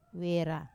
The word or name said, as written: wera